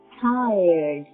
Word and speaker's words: tired: 在这里呢，我们需要注意一下 tired 这个形容词它的发音。 tired, tired，在这个单词里面有一个三元音 tired。